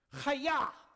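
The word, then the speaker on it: Chaya (חיה): The Hebrew original is pronounced like the very stereotypical karate noise. Chaya!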